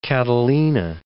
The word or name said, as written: Catalina